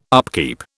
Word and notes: upkeep: British English

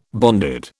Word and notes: bonded: British English

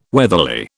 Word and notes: weatherly: British English

